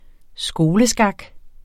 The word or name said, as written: skoleskak